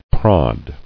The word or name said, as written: Prod